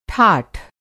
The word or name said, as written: ठाठ